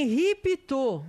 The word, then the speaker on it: Henri Pitot: É Henri Pitot, é francês.